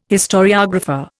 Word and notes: historiographer: Indian

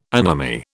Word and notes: anomy: British English

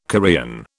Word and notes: Korean: British English

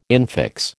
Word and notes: infix: American English

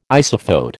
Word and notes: isophote: American English

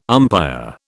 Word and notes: umpire: British English

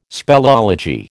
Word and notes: speleology: American English

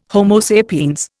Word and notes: Homo sapiens: Indian

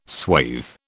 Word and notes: swathe: British English

